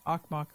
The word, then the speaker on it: Okmok: If you look up KUCB, Akhmuk, that's spelled O-K-M-O-K, volcano.